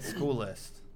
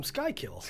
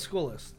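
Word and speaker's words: Schuylkill: Speaking of school list, yours, Christian, the other day kept pronouncing, what'd you say? You called him Skykill. Aaron's school list, he just kept saying Skykill.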